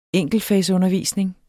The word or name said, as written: enkeltfagsundervisning